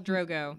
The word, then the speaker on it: Drogo: You see, I'm going to mispronounce their names, but Drago. – Dragon. Khal Drogo.